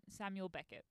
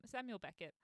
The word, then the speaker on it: Samuel Beckett: And the playwright is none other than Samuel Beckett. I'm saying that right, am I? – Yeah. – Samuel Beckett.